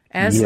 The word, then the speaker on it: Asleson: And am I pronouncing your last name correctly? – Asleson? Yes, you are. It's pronounced a bunch of different ways, and I love it every time.